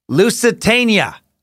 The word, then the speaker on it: Lusitania: It's pronounced Lusitania. Dan, I'm probably still saying it wrong. Loose, yeah, te, nia, Lusitania, Lusitania.